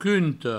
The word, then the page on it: qunte: provençal